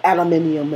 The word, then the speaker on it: aluminium: It's so Mac-like, so everything is just, even the color, it matches the aluminum color of the mat, and aluminium, as the British would say. And I was wondering, who discovered the word aluminum?